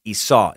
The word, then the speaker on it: Esau: Juan wrote, Master Sucker, the name you are struggling with is Esau. That's that E-S-A-U. Excuse me, E-S-A-U. It's pronounced Esau. It's a weird-ass word for sure, but that's the correct pronunciation.